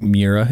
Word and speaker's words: Miura: Can you imagine owning a Lamborghini like Miura?